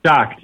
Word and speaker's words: Schacht: Schacht, yeah, more awesome.